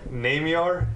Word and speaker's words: Naimyar: Namyar, N-A-I-M-Y-A-R.